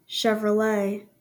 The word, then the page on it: Chevrolet: ˌʃɛvrəˈleɪ; SHEV-rə-LAY